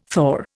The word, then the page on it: thaw: Indian